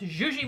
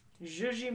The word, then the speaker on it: Yuyi: It's pronounced Zhuzhi Morales. – Zhuzhi Morales. – Yes. Dreamers by Zhuzhi. – Zhuzhi. – Yeah. – Interesting. And it's spelled Y-U-Y-I.